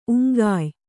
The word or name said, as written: ಉಂಗಾಯ್